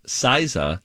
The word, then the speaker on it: SZA: For not to be confused with Siza, whose name is actually pronounced SZA, S-Z-A. Yeah.